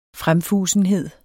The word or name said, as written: fremfusenhed